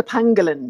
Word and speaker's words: pangolin: The pangolin, yes, the pangolin.